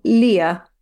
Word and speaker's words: Lier: It's spelled L-I-E-R. Lier. Lier. No, that's a bit French sounding. That's the wrong accent.